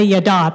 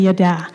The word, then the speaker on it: yada: I looked up how to say it, too. I want to say yada, but it's actually yada.